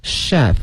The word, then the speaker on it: chef: 但是今天我们学的是一个职业的厨师，叫 chef C-H-E-F 这个词啊，大家一定要小心了它是 CH 的两个字母，但是不要念成我们说另外一个音，它是这个诗音 chef，因为呢它是一个法语词，所以大家一定要小心了，这个发音叫 chef。